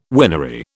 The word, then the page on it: winery: British English